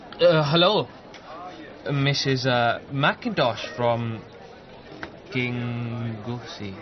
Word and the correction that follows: Kenoosey: It's Kenoosey, love. Pronounced Kenoosey.